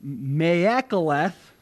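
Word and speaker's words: maakeleth: This word in the Hebrew is me'ekaleth. I'm not Hebrew, so if I'm saying it wrong, don't judge me. Me'ekaleth, it's M-A-A-K-E-L-E-T-H.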